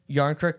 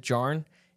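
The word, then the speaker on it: Jarnkrok: Are you sure you pronounced that name right? – Yarncrook, yeah, I did. I actually was going to pronounce it Jarn, and then I remembered it being said, probably when that goal was scored, and I was like, oh, that's Yarncrook.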